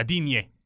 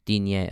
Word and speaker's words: Digne: Yeah, well, and then the ball from, is it Digne? I think it's Digne, right? – Luca Digne. – Digne, okay. – Digne. – I knew I was mispronouncing.